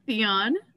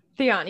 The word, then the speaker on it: Theon: Um, Theon. Theon.